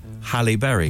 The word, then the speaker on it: Halle Berry: How do you pronounce her name? – Halle Berry. – That's it. – Is it? – Well done. – Yeah. – Like halitosis.